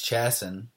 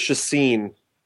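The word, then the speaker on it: Chacín: And then moving on to another guy who's got a few innings under his belt at the major league level, Ulysses Chasson. Hopefully I didn't butcher his name. – I believe it's Chasson. – Chasson? There's no name. – What? – I think it's Chasson.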